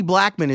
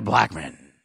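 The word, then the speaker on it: Blackmon: Look at what Charlie Blackman is doing for Colorado. Wait, I didn't say it right. Charlie Blackman.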